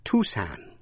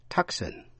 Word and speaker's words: Tucson: Thủ phủ, tiểu bang Arizona, có âm C câm, đọc là Tucson, chứ không đọc là Tucson.